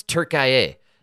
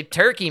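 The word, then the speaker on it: Türkiye: The reason no one's paying attention is because they mispronounced it. It's Turkiye. I know. Nobody they don't understand the gravity of the situation. If you just say Turkey, man, it sounds delicious.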